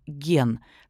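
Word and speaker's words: ген (源): Иероглиф, которым пишется фамилия Тайра, читается еще по-другому – «хей», а иероглиф Минамото – «ген».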